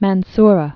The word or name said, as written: El Mansura